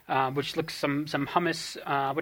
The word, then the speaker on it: hummus: I believe it's pronounced humus.